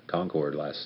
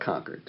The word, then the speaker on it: Concord: My wife and I rode out to Concord yesterday. – We call it Concord. – Concord, excuse me.